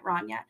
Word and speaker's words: Rania: And sorry, I said Reina before and I meant Rania.